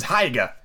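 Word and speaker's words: Haigha: His name is Heiger. He pronounced it so to rhyme with mayor.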